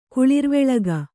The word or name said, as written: ಕುಳಿರ್ವೆಳಗ